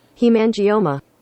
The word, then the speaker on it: hemangioma: Here's how you actually pronounce it. – Hemangioma. – There you go. Nailed it.